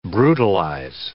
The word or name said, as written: brutalize